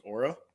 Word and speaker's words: Oura: I want to make sure I, I am saying that right. Aura? That's Aura. what I've always thought. – I think it is. I mean, it's O U R A, right? So it's Aura.